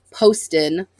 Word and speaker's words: Poston: It's Poston, like Post-it, but with an O-N.